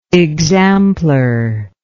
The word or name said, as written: examplar